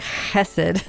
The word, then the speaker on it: hesed: Because what a reminder that your hesed, or if I say it correctly in the Hebrew, it's like hesed, it continues forever, this loyal covenantal love.